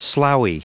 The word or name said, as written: sloughy